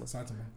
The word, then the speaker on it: Saitama: Saitama.